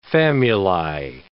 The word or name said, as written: famuli